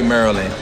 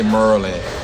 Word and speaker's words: Maryland: You don't say Maryland. You say Merlin.